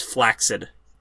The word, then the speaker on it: flaccid: I believe that you should pronounce things how you want, but technically the correct pronunciation of what people say flaccid is flaccid.